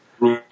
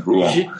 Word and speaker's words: Rouen: Rouen, the second syllable is the stress one. Rouen.